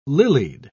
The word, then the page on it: lilied: British English